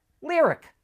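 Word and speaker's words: Lyriq: As you probably know, all of Cadillac's new EVs end in IQ, which is supposed to be pronounced "Ick," "Lyric," "Celestic."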